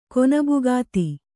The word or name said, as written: ಕೊನಬುಗಾತಿ